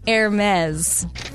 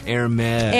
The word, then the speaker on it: Hermès: I go, oh my gosh, this one person we know at work has a Hermès bag. But it's Hermes. – Hermes. – Hermes.